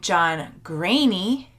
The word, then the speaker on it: Greaney: This is name day because, as you will see, I am interviewing John Greaney. That's how you say it.